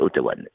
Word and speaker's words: Ottawan: ils ne disent pas vraiment Ottawan, ils disent souvent Ottawa, alors que ça me dérange un petit peu parce qu'ils écorchent un petit peu le nom. C'est Ottawan, oui, bon, donc ça vient du Canada, de la ville d'Ottawa.